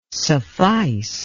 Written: suffice

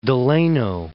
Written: Delano

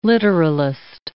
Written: literalist

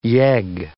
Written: yegg

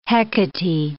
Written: Hecate

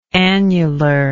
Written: annularly

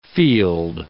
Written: field

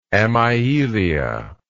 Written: amyelia

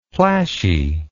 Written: plashy

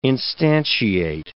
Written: instantiate

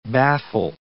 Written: baffle

